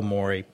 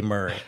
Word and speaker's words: Maury: that the family that I know, they pronounce it Mari. The higher north you go, Maury. Um, and he told me a funny thing. He said, "Look here," he said, "the family can pronounce that however they like, but in this county we say Murray."